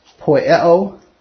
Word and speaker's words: poieo: Poieo, my pronunciation, P-O-I-E-O in the Greek, spoke of a course of action, conduct, to do, does, commit is a word.